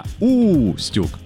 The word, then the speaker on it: Устюг: Это родина Деда Мороза связана с ее расположением в устье реки Юг, но правильное ударение все равно на первом слоге. У! Не Юг, а Устюг.